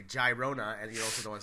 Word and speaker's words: Girona: Girona!